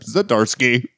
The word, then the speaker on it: Zdarsky: Daredevil number 4, written by Chip Zdarsky.